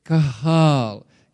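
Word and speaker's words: Kahal (קהל): Okay, if you read this again, in the midst of the assembly, the Hebrew word for assembly is K-A-H-A-L, kahal. Can you say that with me? Kahal. Very easy. Turn to your neighbor and say it. Kahal. K-A-H-A-L, kahal. So the Hebrew word kahal is translated here into English as assembly, right?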